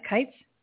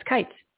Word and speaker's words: Kites: Kites? Did I say it right? – Kites. – Kites. – Kites yeah. – Like knights. – Kites like knights.